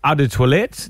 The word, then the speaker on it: Eau de Toilette: Eau de Toilette, that one. – Yeah, that one. – That is the correct pronunciation too.